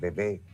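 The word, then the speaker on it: Vevey: ¿Saben que Nestlé, que la tiene la sede en Bebé, se pronuncia así, la parte francesa de Suiza, estuve también allí visitando la empresa, la sede central donde se originó?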